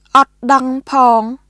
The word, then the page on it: អត់ដឹងផង: オット・ダン・ポーン; ʔɔt dəŋ pʰɔːŋ